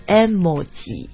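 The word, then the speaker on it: 絵文字: 从外面的语言借到英语里的词。那我看了一下现在我发现 the correct pronunciation, at least in Japanese, will probably be emoji.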